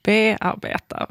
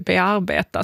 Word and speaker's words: bearbeta: Alltså att det här B, det är ett obetonat prefix i typ alla ord. Och varför tar det då helt plötsligt betoning i bearbeta? Varför säger vi inte bearbeta? Som vore det rimliga. Alltså din tyska föreläsare, han sa ju egentligen det som var rimligt.